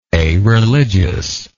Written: areligious

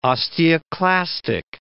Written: osteoclastic

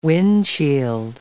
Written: windshield

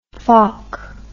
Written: falk

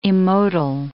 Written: immotile

